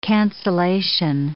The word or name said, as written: cancellation